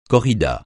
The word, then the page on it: corrida: francais